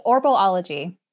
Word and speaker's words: Orbalology: It's Orbalology.